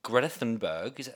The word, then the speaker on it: Greta Thunberg: I want to make sure that we're differentiating Greta Thunberg.